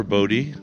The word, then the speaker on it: Boddy: Okay, it's uh, Trevor Boddy, Long O.